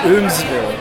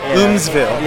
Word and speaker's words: Eumeswil: Yeah, but concerning the book, he mentions in there that it's pronounced Eumeswil. – Eumeswil. – Yeah, and he says he pronounces it in a French way,